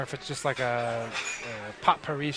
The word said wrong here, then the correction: potpourri: Now you don't pronounce the T in potpourri. – What do you mean I don't pronounce it? – I mean, you just did, but you're not supposed to. – How's it pronounced? – Potpourri.